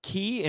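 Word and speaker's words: ki (木): The green ranger, his is pronounced ki, and it means forest or tree.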